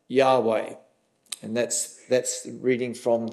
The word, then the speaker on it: Yahweh: But if you look at the Old Testament Hebrew, the name of God is as close as we can put it in English, Yahweh. Although some versions of the Bible translate it as Jehovah, but we believe there is significant evidence to suggest that Jehovah is not the correct pronunciation.